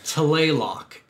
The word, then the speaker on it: Tlaloc: T-L-A-L-O-C. – But I think they really don't pronounce that T, it's like Tlaloc. Like, it's really weird, but I'm going to call it Tlaloc, it's way easier to say.